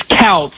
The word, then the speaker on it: Kouts: Is it Coates or Coots? How do you say that? K-O-U-T-S. – It's Couts.